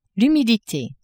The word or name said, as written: humidite